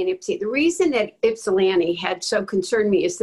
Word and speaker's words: Ypsilanti: Congresswoman, I think the last time around, you talked about being out in Ypsilanti. Am I pronouncing it right? – Ypsilanti, Near Ann Arbor, and just seeing most of the houses having Trump signs.